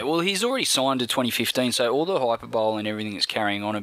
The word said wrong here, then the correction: hyperbole: about him playing the hyperbole, see? You can pronounce things like shit too. Hyperbole is the word you're looking for. Hyperbole.